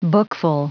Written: bookful

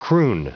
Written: croon

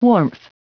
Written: warmth

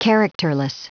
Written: characterless

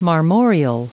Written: marmoreal

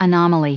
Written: anomaly

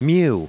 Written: mew

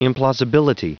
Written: implausibility

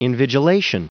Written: invigilation

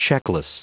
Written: checkless

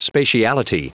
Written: spatiality

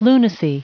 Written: lunacy

